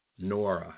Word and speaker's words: menorah: And now there's the spirit, the small spirit, the manure. My brother Shamar told me how to say this, but I tell you, I can't. Menorah. Menorah, that's it.